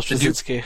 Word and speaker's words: Straczynski: Michael Straczynski.